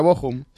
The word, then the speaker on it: Bochum: creo que se pronuncia Bochum.